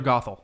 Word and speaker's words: Gothel: Gothel. That's it. Mother Gothel. Mother Gothel. G-O-T-H-E-L.